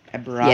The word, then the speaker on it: Abrahamic: You know, there's that whole idea of like, well, before, I don't know if like the Abrahamic, am I saying that right? – Abrahamic. Yes. Feels good.